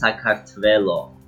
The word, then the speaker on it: Sakartvelo: Yeah, it's pronounced Sakartvelo.